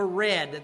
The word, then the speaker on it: redd: at the bottom of a red. That's spelled R-E-D-D.